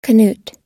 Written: canute

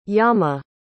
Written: yama

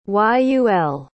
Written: Yul

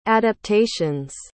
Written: adaptations

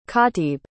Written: kaatib

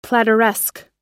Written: plateresque

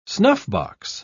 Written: snuffbox

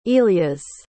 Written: ilias

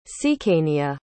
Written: cecania

